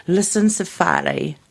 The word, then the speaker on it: lissencephaly: and it is lissencephaly. How would you say it? – Lissencephaly. – Yes.